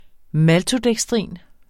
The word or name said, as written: maltodekstrin